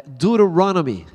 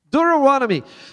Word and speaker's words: Deuteronomy: E aí eu aprendi esses dias como é que fala Deuteronômio em inglês. Vocês manjam? Vocês manjam Deuteronômio em inglês? É Deuteronomy, ah, com accent, um negócio chique. Deuteronomy.